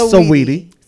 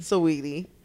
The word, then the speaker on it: Saweetie: Do you know a Sweetie fan? We even saying her name wrong. We've been sitting Saweetie. Up here this whole time, Sweetie. – Right, Sweetie. – It's Saweetie. – Saweetie.